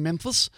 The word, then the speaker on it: Memphis: Lisa Janine Finley changing her plea to guilty Tuesday, this morning actually, during a hearing in Memphis. Notice I do the Elvis pronunciation of Memphis.